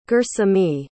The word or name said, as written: gersemi